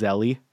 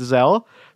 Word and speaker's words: Zelle: Yeah, it's spelled Z-E-L-L-E, which the first time I read it, I thought it was Zelly. But no, it's Zelle.